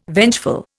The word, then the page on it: vengeful: Indian